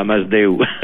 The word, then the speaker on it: Masdeu: Oui, monsieur Jacques Masdeu Arus. – Oui, voilà, vous prononcez en catalan là, Masdeu.